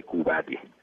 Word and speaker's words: Couvade: Se llama síndrome de Cubade. Se escribe C-O-U-B chica o B Dental A-D-E.